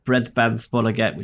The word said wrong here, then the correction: Bredbandsbolaget: which I can't pronounce, I'm not going to try. – The what? – Broadband provider, breadband spolaget. That's why. – Bredbandsbolaget. – Yeah, that's what I said.